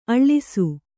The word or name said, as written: ಅಳಸು